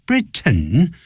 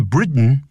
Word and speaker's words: Britain: He came of age during the American Revolution and strongly believed that the United States needed to be culturally independent from Britain. 因为这美式的英文叫 Britain，他用 ten 的音。